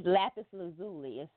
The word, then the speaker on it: lapis lazuli: And this is a, I don't know how to pronounce some of these crystals, but a lapis, what, how do you pronounce it? – It's lapis lazuli.